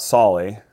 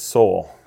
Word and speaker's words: Solly: My name is Scott Solly. A lot of people might see my name and mispronounce it as Soul, which is really where my business name, Soul Gear, originated.